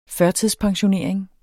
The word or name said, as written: førtidspensionering